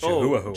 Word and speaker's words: Chihuahua: And this dog knew me, but every single time it would see me, it would just You know that's not how you say that, right? – What? – It's pronounced Chihuahua.